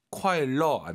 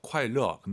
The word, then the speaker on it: 快乐: 快乐 안 돼요. 르 어예요. 快乐.